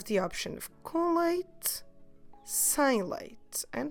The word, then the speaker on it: sem: so maybe you should say sem leite. Don't forget when you pronounce sem to leave your mouth open in the end.